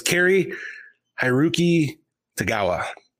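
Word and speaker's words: Cary-Hiroyuki Tagawa: How do you say that? – So I'll take my shot at this. I think it's Carrie Hiroki Tagawa.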